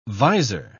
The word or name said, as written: visor